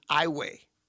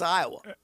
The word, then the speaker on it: Iowa: He lives in Peoria, but he says, I'm currently parked in Iway, Louisiana. Now, that's another funny pronunciation because other people would say that's Iowa.